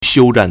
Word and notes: 休战: xiūzhàn

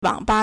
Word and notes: 网吧: wǎngba

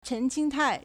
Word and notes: 陈清泰: 陳清泰; chén qīngtài

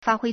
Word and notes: 发挥: 發揮; fāhuī

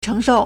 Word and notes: 承受: chéngshòu

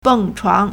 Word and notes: 蹦床: bèngchuáng

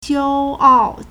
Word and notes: 骄傲: jiāoào

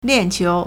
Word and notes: 链球: liànqiú

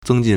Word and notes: 增进: zēngjìn

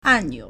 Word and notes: 按钮: 按鈕; ànniǔ

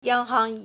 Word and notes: 央行: yāng háng

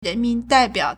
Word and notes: 人民代表: rénmín dàibiǎo

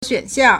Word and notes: 选项: xuǎnxiàng

